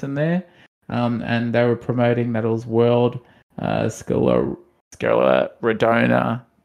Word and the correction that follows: Scleroderma: Sorry, Day. I've definitely mispronounced that. Scleroderma Day, World Scleroderma Day.